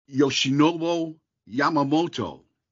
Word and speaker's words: Yoshinobu Yamamoto: Yoshinobu Yamamoto is a Dodger, and I think I said that right, right? – Yeah, you got it.